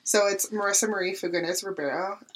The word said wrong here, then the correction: Fagundes: Now say it right because it's Marisa Marie Fagundes Ribeiro. There it is. That's the real version of my.